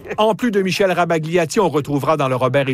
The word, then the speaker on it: Rabagliati: L'auteur des bandes dessinées de la série Paul, Michel Rabagliati, aussi prononcé Rabagliati, fait son entrée dans le Robert illustré 2027.